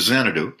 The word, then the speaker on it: Xanadu: I saw a couple comments that that's not real, but that was in Malibu, which rhymes with Xanadu, up on a mountaintop overseeing the Pacific Ocean.